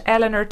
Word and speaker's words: Eleanor: It's at Eleanor Tiernan, ELEANOR TIERNAN.